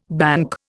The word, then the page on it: banc: Indian